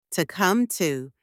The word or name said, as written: to come to